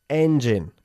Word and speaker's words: engine: Engine. Enfatizando engine. La primera sílaba. Engine.